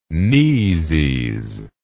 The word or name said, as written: kneesies